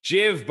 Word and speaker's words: Jiv: It's Jiv, by the way. Nobody ever pronounces it right because it seems like it's Spanish on the front end, but not Spanish on the back end.